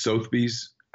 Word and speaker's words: Sotheby's: give Lauren Ballack Ferris with Briggs Freeman Southpiece a call. A rebel by marriage. Someone corrected me on the pronunciation of Southpies. It's Sotheby's.